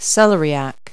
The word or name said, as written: celeriac